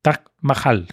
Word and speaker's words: Taj Mahal: ¿Conocéis el Tal Mahal? Sí, claro. Taj Mahal, que no lo pronuncio, He dicho Tal Mahal y tal no es, es Mahal.